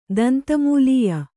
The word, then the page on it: ದಂತಮೂಲೀಯ: danta mūlīya